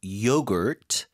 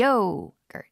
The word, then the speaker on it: yogurt: Making Greek yogurt lately. 자, 요거트 발음이 좀 어렵습니다. 발음 다시 한번 드릴게요. – Yogurt. – Yogurt. – 네. 네. Yogurt. 그쵸? Yogurt. – Yogurt. 이렇게 앞에 힘주고 발음하시면 돼요.